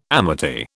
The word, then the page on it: amity: British English